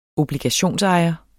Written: obligationsejer

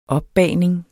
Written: opbagning

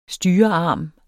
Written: styrearm

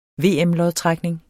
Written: VM-lodtrækning

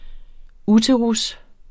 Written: uterus

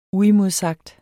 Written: UIMODSAGT

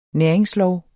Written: næringslov

